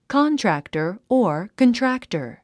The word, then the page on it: contractor: kάntræktər; カントラクタァ